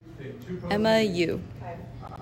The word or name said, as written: Emma Yu